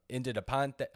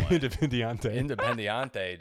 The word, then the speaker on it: Independiente: He joins from Independiente. Oh, wow, I butchered that one. – Independiente.